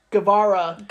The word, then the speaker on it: Guevara: Now, how do you pronounce his last name? – Guevara.